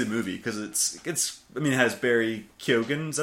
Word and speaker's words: Barry Keoghan: His name is Barry Keoghan, if you can see that.